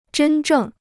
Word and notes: 真正: zhēn zhèng

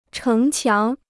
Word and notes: 城墙: chéng qiáng

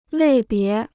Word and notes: 类别: lèi bié

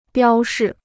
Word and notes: 标示: biāo shì

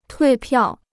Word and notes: 退票: tuì piào

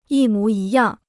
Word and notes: 一模一样: yī mú yī yàng

